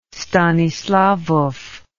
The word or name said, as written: stanislawow